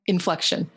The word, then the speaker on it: Inflexion: Inflexion, yep.